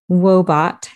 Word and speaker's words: Woebot: One tool I'm not sure if I've shared on here or not is an app called Wobot. That's spelled W-O-E-B-O-T, Wobot.